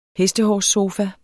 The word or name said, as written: hestehårssofa